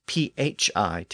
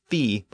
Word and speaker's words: phi: This later became the letter phi, spelled as PHI today. It was probably pronounced as phi by the Greeks, but I'll use the more modern pronunciation phi as in the fraternity and sorority names.